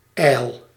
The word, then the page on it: ijl: /ɛil/